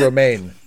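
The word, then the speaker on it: Romijn: Rebecca Roman, I think that's how you pronounced it. – Romaine. – Rebecca Romaine, at the time Romaine Stamos, mm-hmm.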